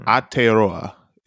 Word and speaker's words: Aotearoa: Aotearoa is how you say it. – Yeah. Well, smoke free that 2025 action plan. New Zealand. – Aotearoa.